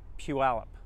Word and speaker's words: Puyallup: It's Puyallup. Puyallup. – Pylap? – Puyallup. – Pylap? – Puyallup. – How you call it? Is it Puyallup? You kind of roll them together.